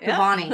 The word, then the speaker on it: Kabbany: Kabbany. – You're saying it right. – Kabbany.